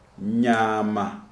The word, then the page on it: Nyama: Shona